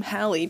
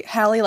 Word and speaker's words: Hallie: It's not spelled like, I always say Hallie like Halle Berry, and they're like, oh, is it spelled the same? And I'm like, well, no, but if you look, Hallie, it says yeah. – Hallie. – Yeah. – It's not Haley or Holly, and it's like, people ask me all the time, why don't you correct me?